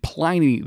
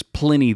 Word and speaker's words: Pliny: There is a Quora article, which we will link into the show notes, with a video of the head brewer at Russian River saying Pliny the Elder versus Pliny the Elder.